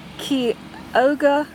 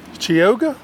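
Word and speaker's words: Chioggia: Okay, here we have the Kioga. – Chioga? – K-E-Y is how it's pronounced. I know that's what it says.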